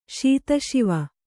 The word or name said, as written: ಶೀತಶಿವೆ